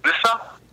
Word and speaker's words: Lissa: L-I-S-S-A. – Lissa? Lissa? – Lissa.